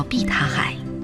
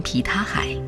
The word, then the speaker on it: 碧塔海: 蓝色的湖泊叫碧塔海，本地人会念成皮塔海，而外地人又会经常说成碧海塔。